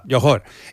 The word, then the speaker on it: Johor: Johor.